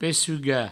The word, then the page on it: pecugar: pessuga; provencal